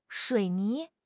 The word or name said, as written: 水泥